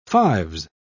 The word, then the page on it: fives: British English